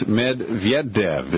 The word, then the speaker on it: Medvedev: Correct, and you just got to slur that those two D's together. In the pronunciation, you know, those aren't written actually in his name. So there's a Medvedev, it's that V-Y-E-D-D-E-V if you want the phonetic pronunciation.